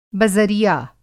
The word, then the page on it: बज़रिया: بزریعہ